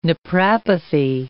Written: naprapathy

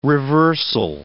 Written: reversal

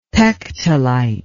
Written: pectolite

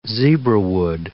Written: zebrawood

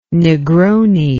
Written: negroni

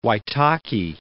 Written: Waitaki

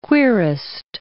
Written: querist

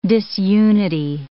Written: disunity

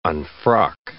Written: unfrock

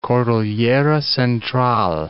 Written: Cordillera Central

